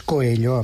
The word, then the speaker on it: Coelho: Diuen exactament, diuen Coelho, i és Coelho amb dues elles, Coelho, caldria que sabessin que en portuguès les lletres L-A que es pronuncia com una doble L, per favor, que no és tan difícil, no sé per què ens molestem quan a la resta de l'estat espanyol diuen Sabadell en lloc de Sabadell, no?